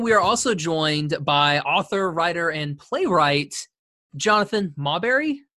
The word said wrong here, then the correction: Maberry: Maberry. Maberry.